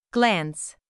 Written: glance

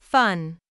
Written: fun

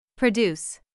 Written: produce

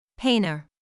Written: painter

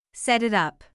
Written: set it up

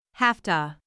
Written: hafta

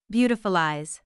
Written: beautiful eyes

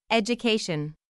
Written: education

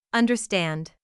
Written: understand

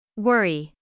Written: worry

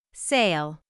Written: sale

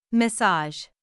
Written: massage

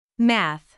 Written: math

